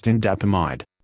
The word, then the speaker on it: indapamide: Pirindopril, pronounced Pirindupril, and Indapamide, pronounced Indapamide.